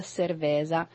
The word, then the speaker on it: cerveza: Nei vari dialetti dello spagnolo e del portoghese la bevanda viene chiamata cerveza, è giusto? – No, fermi. La pronuncia giusta. – Cerveza. – Cerveza.